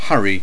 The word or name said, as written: hurry